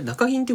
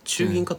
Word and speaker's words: 中銀: あれ中銀って読むんだ。 – 中銀かと思った。中銀なんだ。